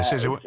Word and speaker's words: Sisu: Sisu? – Yeah. – Okay, Sisu.